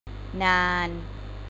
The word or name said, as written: นาน